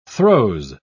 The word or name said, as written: throes